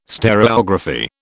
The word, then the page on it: stereography: British English